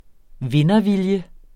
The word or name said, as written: vindervilje